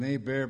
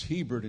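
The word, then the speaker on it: Hébert: It's Hebert in Texas. That's where she came from, where she was living. But it's H silent in French. T is silent. There's an accent aigu on the E pronounced A, so it's A-bear.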